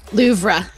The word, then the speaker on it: Louvre: Is it Louvre? Is that right? Someone tell me how to say it right, so I don't sound like an ignorant prick. – Louvre.